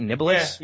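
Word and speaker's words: Niblis: Did I say that right? Nibelous? – Yeah. – Like you're nibbling on something? – Yeah.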